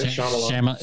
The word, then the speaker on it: Shyamalan: You said it right. Shyamalan.